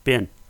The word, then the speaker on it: been: How do you pronounce B-E-E-N? – Ben. Instead of being, I say Ben.